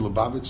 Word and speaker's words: Lubavitch: It's not just an L. It's a different sound in the Cyrillic alphabet. Anyway, so he lived in, we say just an L, Luban, Lubavitch.